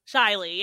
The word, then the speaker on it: Shaily: Shaily, yeah.